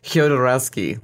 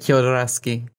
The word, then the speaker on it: Jodorowsky: I always pronounce it Jodorowsky, like it's a Y, like Jodorowsky. – Jodorowsky, Jodorowsky. – You know how Y, like when a Y is in Spanish, you pronounce it like it's a J? – Yeah. – But it's more like a Jo, like it's like both of them together.